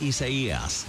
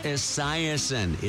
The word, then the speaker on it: Isaias: It's not Isaiasen. It's Isaias. Hurricane Isaias. – Hurricane Isis? I thought we defeated them. – That's what people are saying about Hurricane Isaias.